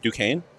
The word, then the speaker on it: Duquesne: Or how do you say that? – Duquesne? – Yeah.